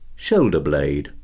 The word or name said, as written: shoulder blade